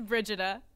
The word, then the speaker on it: Brigida: Did I say that right? Brigida. – It's Brigida.